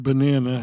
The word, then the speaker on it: banana: The word banana has three syllables. Ba-na-na. Banana. The stress, the accent, is on the second syllable. Banana. Banana. In English, we change the vowels in unstressed syllables. They become what is known as schwa, which is an sound. It's not banana, it's banana, banana, banana.